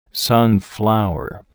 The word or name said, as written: sunflower